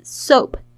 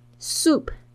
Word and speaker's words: soap: Soap 大家特别注意一下，有很多这个学生会念成 soup，soap and soup 两个不同的东西哦。 Soap S O A P，你要注意这个 O A 的发音是发 O 这个双元音 O soap soup。